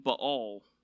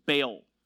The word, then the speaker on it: Baal: Because she believed that it was another god who was providing it to her, a god named Baal. Or if you say it like most of us, Baal. Either one you want to use.